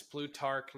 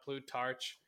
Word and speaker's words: Plutarch: Okay, I learned how to say it. It's Plutarch, not Plutarch. And this passage says,